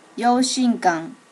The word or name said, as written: yoshinkan